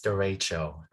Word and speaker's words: derecho: Is it derecho? Am I pronouncing that correctly? – You're correct. It's derecho.